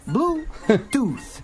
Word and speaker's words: Bluetooth: Isso mesmo, Bluetooth. O final do som de tooth, ele é feito com a língua no meio dos dentes.